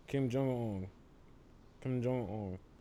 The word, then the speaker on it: Kim Jong Un: He made a tweet about Kim Jong Un. Am I saying his name right? – Yeah. – Okay. Yeah. – Kim Jong Un.